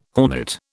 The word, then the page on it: hornet: British English